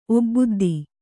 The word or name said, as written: ಒಬ್ಬುದ್ದಿ